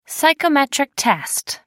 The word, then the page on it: psychometric test: American English